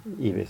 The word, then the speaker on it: Ive: Jonathan Ive, se escribe I latina V-E. Ajá. Ibe sería.